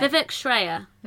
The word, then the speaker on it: Vivek Shraya: Uh, I'm sorry if I'm saying Vivek wrong. – I um, think we're saying it right. Vivek Shraya. Vivek, Vivek Shraya. Vivek Shraya. Vivek Shraya. Okay.